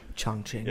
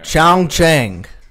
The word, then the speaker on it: Chongqing: Chongqing. – Yep. – And it's like, yeah, Cheech and Chong. My pronunciation was not right on Chongqing. – Chongqing. That's what I call it.